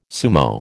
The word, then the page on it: sumo: American English